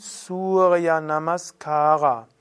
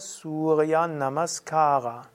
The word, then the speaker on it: Surya Namaskara: Surya Namaskara. Übrigens, wenn wir sagen Surya Namaskar, dann ist das die Hindi-Bezeichnung dafür. Ist aber korrekt, der Sanskrit-Aussprache wäre Surya Namaskara.